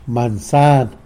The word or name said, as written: mansan